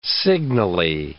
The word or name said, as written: signally